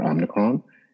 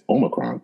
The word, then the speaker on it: Omicron: And of course, now we have a new variant that's out there, Omicron. And I actually just said that wrong. It's Omicron.